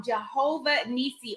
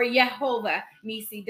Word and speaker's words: Jehovah-Nissi: So this morning we're going to talk about Jehovah-Nissi or Yahweh-Nissi or Jehovah-Nissi, depending on how you want to pronounce it.